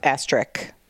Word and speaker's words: asterisk: It's asterisk. – It's not, though. It's spelled asterisk. – Yeah, but that doesn't mean that the X is pronounced. – No, it's not an X. It's an SK.